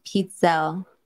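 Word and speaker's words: pizzelle: Also, Aussie said Pizzell is pronounced Pizzell. Oh, there we go. – It's Italian.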